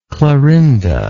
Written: clarinda

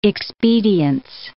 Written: expedience

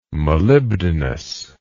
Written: molybdenous

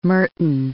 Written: Merton

